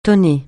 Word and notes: tonner: francais

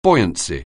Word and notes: buoyancy: anglais

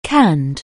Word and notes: canned: anglais

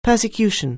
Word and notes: persecution: anglais

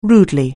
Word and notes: rudely: anglais

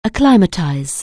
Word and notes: acclimatize: anglais

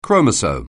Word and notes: chromosome: anglais

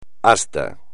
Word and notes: asta: espagnol

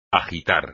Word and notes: agitar: espagnol

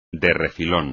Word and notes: de refilón: espagnol

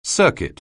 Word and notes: circuit: anglais

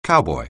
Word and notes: cowboy: anglais

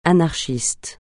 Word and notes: anarchiste: francais

